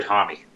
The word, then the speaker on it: Homme: Is it pronounced Hom or Hommy? – I've always heard Hommy.